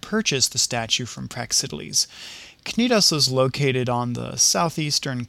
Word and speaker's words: Cnidos: You might also come across the pronunciation Cnidos, but that's just plain silly.